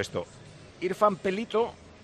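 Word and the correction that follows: Peljto: Pelto. Pone Pel J-T-O. – Eso, Pelto. – ¿La J no es una I? – Sí, pero es muda.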